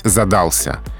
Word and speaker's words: задался: Образец, эталон, э, лучший вариант здесь задался. Именно с таким ударением. Вариант задался допустимый, но менее желательный.